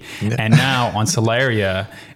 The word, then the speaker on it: Solaria: And now we're on this spacer planet called Solaria. I think I'm pronouncing that right.